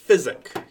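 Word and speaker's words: Fizzic: And this thing is called the Fizzik. – Fizzik, yes. – Spell that. – F-I-Z-Z-I-C, I think.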